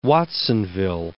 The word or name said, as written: Watsonville